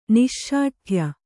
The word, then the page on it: ನಿಶ್ಶಾಠ್ಯ: niśśaṭhya; kannada